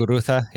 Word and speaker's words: Muguruza: I just saw Garbine Muguruza, I don't know if I'm saying that right. – Muguruza, yeah.